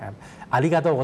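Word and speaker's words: อ าริ กา โต ะ (ありがとう): อาริกาโตะโกไซมัสอาริกาโตะโกไซมัสออกเสียงช้าๆนะครับจะออกเสียงว่าอาริกาโตะ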